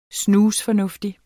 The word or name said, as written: snusfornuftig